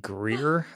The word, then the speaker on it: Greear: I think it's J.D. Greear, I think is how you pronounce his name. It looks like Greear.